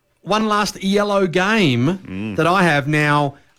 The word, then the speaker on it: Iello: I think that you see that's pronounced Yellow.